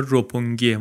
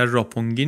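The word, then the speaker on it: روپونگی (六本木): تلفظ درستش هم انگار روپونگیه ما یه خورده بیشتر راپونگی نوشتیم روپونگی انگار در ژاپنی درستتره.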